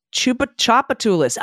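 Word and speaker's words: Tchoupitoulas: Oh, Chupatulus? Pronounced Chupachopatulus. I was close. – You were very close. – I was so close. – There's a T at the beginning of this word, by the way.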